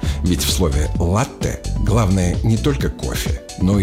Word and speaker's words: латте: Латте или латте. Правильный вариант – латте с ударением на первый слог.